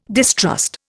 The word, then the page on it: distrust: Indian